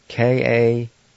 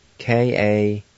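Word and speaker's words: kanoge: and it's spelled T-S-O-L-A-N-I. Again, T-S-O-L-A-N-I. Word number two, wing. And it's spelled K-A-N-O-G-E. K-A-N-O-G-E. Number three is wink. And it's spelled A-G-A-T-A-S-D-I-H-A. Again, A-G-A-T-A-S-D-I-H-A.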